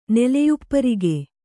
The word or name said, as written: ನೆಲೆಯುಪ್ಪರಿಗೆ